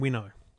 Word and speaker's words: Winnow: called Mission Minnow. – Oh. – Mission Winnow. – Winnow? – Winnow.